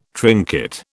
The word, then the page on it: trinket: British English